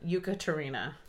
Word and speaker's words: Yekaterina: So, on this plane, we have Stanislav and Yekaterina.